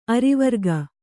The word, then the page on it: ಅರಿವರ್ಗ: arivarga